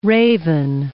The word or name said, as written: raven